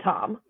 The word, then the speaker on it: Thom: It's definitely Tom. – Yeah. – I think that's what we call a silent H. – It's like, you're fucking stupid. Like, why put an H there? – No, I agree wholeheartedly.